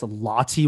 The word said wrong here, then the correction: Lotte: Oh, Lotte World?